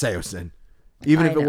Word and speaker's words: Saosin: And then Anthony Green confirmed on Twitter when people how were bringing this video to his attention that the band is called, it's pronounced Seosin. And they're spelled S-A-O-S-I-N.